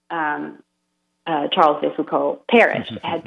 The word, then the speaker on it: Charles: And they all decided to pray through the intercession of Charles de Foucauld, or in French, Charles is pronounced Charles.